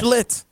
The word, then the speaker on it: Schlittler: The CH? – The Schlittler? – Yes. – Schlitt. – Yes, like a slit.